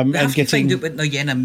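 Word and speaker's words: Nuyina: But anyway, the Nunia, is it Nunia? Nunia? Hang on a second. I've got a pronunciation. – Noyena, isn't it? – Noyena, that's the one.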